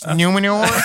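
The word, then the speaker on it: Númenor: It's like they, On the topic of Galadriel and his sort of plot, we talked about Numenir. – What are we talking about? – Numenir. – It's pronounced Numenor.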